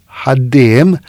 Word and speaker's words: ħaddiem: Jekk mill-banda l-oħra ngħid ħaddiem, ħaddiem, naf illi qed nitfa l-ħafsa tiegħi fuq it-tieni sillaba, l-ewwel nitfa hija twal.